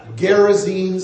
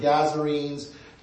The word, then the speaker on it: Gerasenes: Gerasenes, some people pronounce it Gazarenes. Gerasenes we'll go with, in the region around Decapolis.